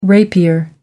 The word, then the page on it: rapier: American English